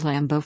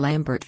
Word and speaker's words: Lambeau: In 2004, John Kerry was in Wisconsin when he butchered the name of the Green Bay Packers' hallowed Lambeau Field, calling the stadium Lambert Field.